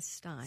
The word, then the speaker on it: Bernstein: And I'm hoping, is it Bernstein or Steen? – It's Bernstein. Mr. Bernstein was a very, very proud Jew. And he always pronounced his, always corrected the pronunciation of his name to Stein.